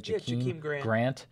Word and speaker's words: Jakeem Grant: Jakeem Grant.